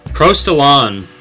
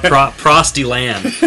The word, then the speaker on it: Prostalon: Prostalon, that would be the French pronunciation. I actually call it Prostalan.